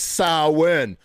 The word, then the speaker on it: Samhain: A lot of you are probably unaware that's properly pronounced Samhain. Yeah, okay? It's like sow, which is like female cow, and then win, like you're winning at life, okay?